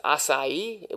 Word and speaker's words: acai: All right, our next superfood is acai, which is spelled A-C-A-I.